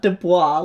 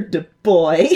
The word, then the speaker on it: Du Bois: Not Du Bois, like I mispronounced earlier. Du Bois.